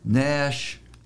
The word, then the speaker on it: gnash: Gnash and snaggle are only, you know, rhyme. They only have, they chime, as they say in Welsh poetry. They chime, but you're using a silent G.